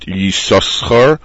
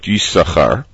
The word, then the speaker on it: Yisachar: In other words, Yisachar is spelled Yud, Sin, Sin, Kaf, Resh, but it's pronounced not Yisachar, but Yisachar, and the reproach is learned from that.